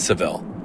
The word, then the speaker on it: Savile: and this guy named Jimmy Savile. How do you say his name? – I think it's Savile. Savile, yeah. – S-A-V-I-L-E.